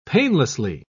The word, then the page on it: painlessly: British English